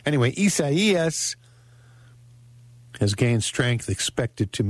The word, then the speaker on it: Isaias: Isaias is how it's pronounced, which is kind of I don't know anybody named Isaias.